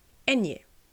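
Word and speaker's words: eñe: Enje. En dat is de N met dat golfje erop. Wat je bijvoorbeeld terugziet in woorden zoals España, piña, cumpleaños. En dat wordt dus in het Nederlands uitgesproken als enje.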